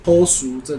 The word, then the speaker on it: 好嶼: 后熟这两个字其实是好语的台语发音。 – 哦，是啊，对，就是好是后，岛屿的语叫熟。